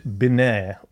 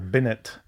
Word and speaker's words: Binet: Alfred Binet, or Binet, depending on your pronunciation.